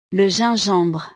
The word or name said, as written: le gingembre